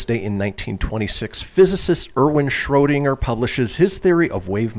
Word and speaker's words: Schrödinger: Schrödinger.